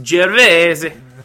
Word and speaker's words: Gervais: How do you say that? – Ricky Gervais. Isn't that how you say it? – It's Gervais. – All right, I definitely can't say that. – It's Gervais. – It's Gervais. – Oh, I thought it was Gervais.